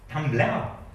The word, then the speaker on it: Tumblr: Tumblr. – Warum scheißt man dann nicht? Da müssen wir einen fertig. Man hat völlig fertig gemacht. Okay, ich fange nochmal von vorne an. Sondern bei Tumblr, oder wie das heißt.